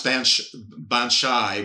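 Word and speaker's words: baobhan sith: But basically, it's pronounced Banshai, which is a banshee.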